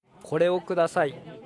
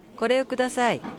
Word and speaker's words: これをください: Послушайте, как разные люди произносят эту фразу. – これをください. – これをください.